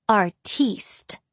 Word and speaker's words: artiste: he said sarcastically, pronouncing it the French way, artiste.